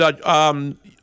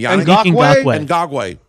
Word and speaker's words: Ngakoue: And Ngakoue? I know I'm mispronouncing his last name. – Ngakoue.